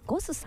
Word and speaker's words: GOSS: ゴスさん、 GOSS と書いてね、ゴスさん、そしてお寿司屋さんが 2店舗、お魚のブリ 2 門と書きましてシモンというお店が銀座に 1 店舗、日本橋に1 店舗ございます。